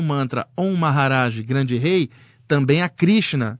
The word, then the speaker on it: Maharaj: E aí eu fazia esse mantra, que era uma palavra que os sábios usavam, que é o mantra Maharaj. E em todos os mantras hindus se usa antes o Om, que é a vibração do universo. Om Maharaj. Se escreve M-A-H-A, Maha, Iraj, R-A-J, Om Maharaj também, o Om Maharaj.